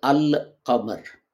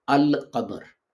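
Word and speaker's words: al qamar (القمر): But If you add al to it, it becomes al qamar. Al is pronounced, lam is pronounced, and lam of al is pronounced in full, al qamar.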